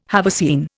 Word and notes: haversine: Indian

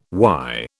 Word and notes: why: British English

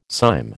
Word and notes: cyme: American English

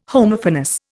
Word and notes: Homophonous: Indian

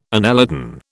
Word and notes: annelidan: British English

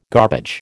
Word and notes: garbage: American English

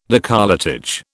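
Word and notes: décolletage: British English

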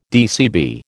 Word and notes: DCB: American English

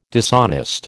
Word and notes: dishonest: American English

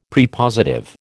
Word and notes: Prepositive: American English

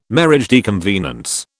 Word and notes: mariage de convenance: British English

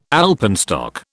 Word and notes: alpenstock: British English

